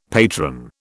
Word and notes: patron: British English

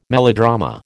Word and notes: melodrama: American English

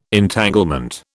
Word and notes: Entanglement: British English

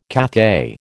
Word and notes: Cathay: American English

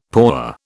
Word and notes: paua: British English

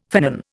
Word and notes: finnan: Indian

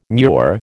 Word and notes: mure: American English

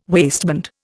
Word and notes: waistband: Indian

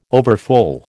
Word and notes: overfull: American English

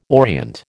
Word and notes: orient: American English

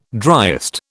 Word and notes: driest: British English

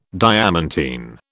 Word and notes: diamantine: British English

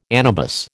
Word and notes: anabas: American English

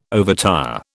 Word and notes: overtire: British English

